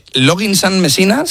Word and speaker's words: Loggins and Messina: Muy buen tema este, muy buen tema y quizá no demasiado, no demasiado conocido, de Loggins and Messina, se dice así.